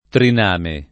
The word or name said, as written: triname